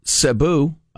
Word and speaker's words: Cebu: Cebu, I looked up how to pronounce it because mm.